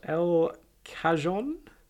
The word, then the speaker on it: El Cajon: Then you've got El Cajon. – Sure. I feel like you nailed that pronunciation, by the way.